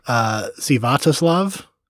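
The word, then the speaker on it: Sviatoslav: His name, Stanislav Richter. – Mm. I think that's actually, it looks like it's pronounced Svyatoslav.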